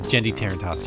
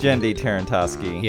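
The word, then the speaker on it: Genndy Tartakovsky: Gennady Trotsky? I forget how to pronounce his name. – Oh, Jendy Tarantosky. – Jendy Tarantosky.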